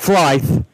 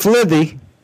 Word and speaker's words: Flythe: You look up On the Fly, and when I say On the Fly, Fly is F-L-Y-T-H-E. This is my last name, and it's not Flythe. It's not Filthy. It's not Flythe. It's Flythe. Fly. And see, you made me say it wrong. Fly. The T-H-E is silent.